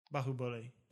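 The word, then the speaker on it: Baahubali: Pronounced Bahubali. – Bahubali. – Bahubali. Okay. So we've been doing it. – We're right. Not too. We're just saying it like Americans.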